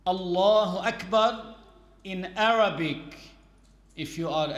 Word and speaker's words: Allahu Akbar: Not to say Allahu Akbar with ga. As you say garden, some people, if instead of saying Allahu Akbar, they say Allahu Akbar. This is invalid.